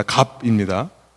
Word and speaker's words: 갑: 말하자면 오늘날로 말하면 유대인 종교 지도자들 오늘 본문 11절에서 대제사장들이라고 표현한 그 종교 지도자들은요 오늘날로 말하면 갑들이에요 갑 발음 잘 하시기 바랍니다 갑입니다 쌍기역이 아니죠 죄송합니다 그리고 빈민구역 유대인을 중심으로 시작된 초대교회라고 하는 것은요 을이에요 을입니다.